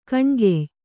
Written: ಗೋನಿಕಾಯ

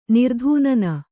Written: ನಿರ್ಧೂನನ

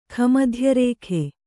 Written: ಖಮಧ್ಯರೇಖೆ

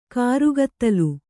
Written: ಕರ್ಗತ್ತಲೆ

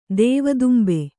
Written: ದೆವ್ವದುಂಬೆ

